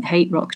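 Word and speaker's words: HTRK: Um, so it's a bit electronica, a bit dark, brooding, but I love everything Hate Rock do. It's H T R K, but you say you pronounce it Hate Rock.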